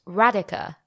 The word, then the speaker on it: Radhika: Radhika got back to me and she said, it's pronounced Radhika, like radical.